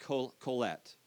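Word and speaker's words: Qoheleth: it comes from the Hebrew, which is pronounced kolet, okay, kolet. And kolet is translated teacher or preacher, and you see this person named throughout the book.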